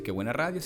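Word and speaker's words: K Buena Radio: Estamos en K Qué Buena Radio, ok, así se dice Qué Buena Radio, se escribe K, Buena Radio,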